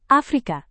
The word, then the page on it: afrika: indonesian